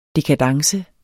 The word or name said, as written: dekadence